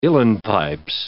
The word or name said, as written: uilleann pipes